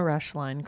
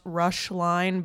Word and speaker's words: Roeschle: And my name is pronounced Rushline, but it's spelled Roeschle.